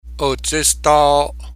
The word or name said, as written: Otsestaoo'o